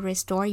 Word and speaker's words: restore: 但是要注意，这个字里面的 t 因为位置在 s 后面的关系，发音的时候要发成的的音，而不是 t 的音哦。 You must get enough rest to restore your health.